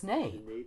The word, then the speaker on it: nape: I've only pronounced it as nape. – Yeah, and I as well, until this was brought up to me and I looked it up and discovered that it could be pronounced nap.